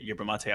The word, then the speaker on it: yerba mate: First off, like, I'm so glad you pronounced it yerba mate.